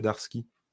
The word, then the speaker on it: Darski: Et en fait, après, euh, pourquoi il disait Darski Parce qu'il paraît que c'est comme ça qu'il faut le prononcer.